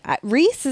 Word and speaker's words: Rhys: Rhys, is that how you say it? I believe so.